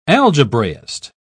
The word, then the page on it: algebraist: British English